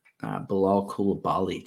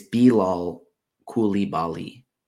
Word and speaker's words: Coulibaly: So this isn't the first one, but Bilal Coulibaly just went off. Or no, excuse me. It's Bilal Coulibaly.